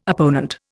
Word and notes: opponent: Indian

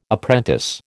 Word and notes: apprentice: American English

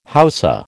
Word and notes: Hausa: American English

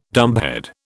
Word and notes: dumbhead: British English

